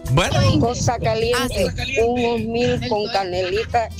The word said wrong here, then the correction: oatmeal: Eh, no se dice osmil, se dice oatmeal.